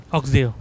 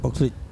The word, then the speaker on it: Oxlade: Oxdale. – How do I say this? – Oxlade.